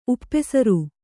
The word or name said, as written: ಉಪಸರ